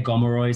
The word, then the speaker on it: Gomeroi: Some people say Gomeroi, some people say Kamilaroi. There's lots of pronunciations and spellings.